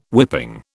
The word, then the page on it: whipping: British English